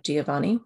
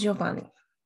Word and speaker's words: Giovanni: It matters for this episode because, as you will hear, I'll be using the English pronunciation of Giovanni, which has four syllables, because that's what the poetic meter requires. The Italian pronunciation is Giovanni, so only three.